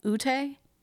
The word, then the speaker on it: Ute: It's UTE is the Native American tribe. Ute? – That sounds right. I think it's Ute.